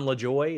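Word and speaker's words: Lajoie: John LaJoy.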